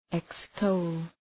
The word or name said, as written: extol